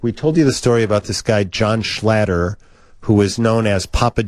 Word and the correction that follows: Schnatter: Schnatter. – Mm-hmm. What did I say? Schlatter? – It's spelled Schnatter. – Schnatter. Papa John Schnatter.